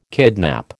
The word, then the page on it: kidnap: American English